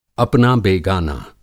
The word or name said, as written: अपना बेगाना